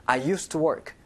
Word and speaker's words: used to: used to라고 발음하지 않고요. 연음 때문에 used to. used to. 그러니까 중간에 used, d 발음이 없어지는 거예요. I used to work.